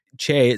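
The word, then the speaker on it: Tchê: Because it's like Che, it's spelled T-C-H-E, which is a Brazilian colloquialism.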